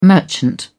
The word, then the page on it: merchant: British English